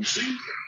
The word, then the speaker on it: Цзинь: Я произносил «дзынь» с первым звуком «ц», хотя действительно для, так сказать, русскоязычного уха это действительно напоминает то, как мы описываем, так сказать, передаем на письме звук звонка дверного.